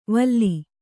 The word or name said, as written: ವಲ